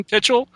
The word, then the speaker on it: Pichel: This was produced by George Pal and directed by Irving, and I don't know how to say his name, Pichel? – I would sure. Is it that simple? – Sure, yeah. why not? – Irving Pichel and stars Jimmy Durante.